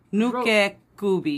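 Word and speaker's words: Nukekubi: Nuke gubi, right? – Nuke kubi, yeah, that's the yeah.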